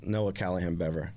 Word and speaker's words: Noah Callahan Bever: Noah Callahan Beaver. – My guy. Like, or Bever, am I saying it right? – Noah Callahan Bever.